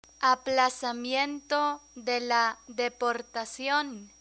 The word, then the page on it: aplazamiento de la deportacion: spanish